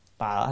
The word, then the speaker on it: tự: tợ hay tự cũng được, chúng ta hay đọc là tự.